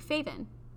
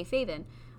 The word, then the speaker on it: Feven: So my name is spelt Feven, but my parents have always called me Faven. – With a V. – Like, the way they pronounce it in my language, and the way my grandma says it, and, like, my aunts and uncles and everybody back home, they say Faven.